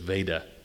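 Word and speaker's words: Vajda: Father Veda, it's, by the way, it's V-A-J-D-A, but I understand the authoritative pronunciation is Veda, like Darth.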